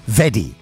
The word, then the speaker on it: veddy: And I'd have used that very that only posh people say, which is the one that's spelled V-E-double-D-Y. Veddy.